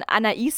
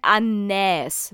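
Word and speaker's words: Anaïs: Und bei Anais, dass man Anais sagt im Französischen und nicht Anais, weil eigentlich ist ja AI im Französischen Ä.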